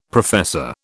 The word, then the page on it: professor: British English